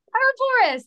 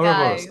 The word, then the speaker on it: Ouroboros: Is it Ouroboros? Is that Go anywhere again. Close? Oh, Jesus. Ouroboros. – Ouroboros, there you go. – Ouroboros, okay. Second go, that's not bad.